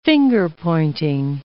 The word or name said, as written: finger-pointing